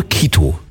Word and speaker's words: Quito: Ich glaube Quito. Also geschrieben wird es mit Q, also Q-U-I-T-O, also so ich als alter Spanier würde Quito sagen. Quito, sí, ja, grande cerveza Quito, por favor. – Quito? – Quito, por favor, Was heißt denn Quito?